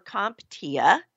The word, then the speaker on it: CompTIA: Now, in September of 2020, this year, CompTIA, or CompTIA,